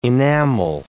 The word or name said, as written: enamel